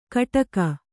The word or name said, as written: ಕಟಕ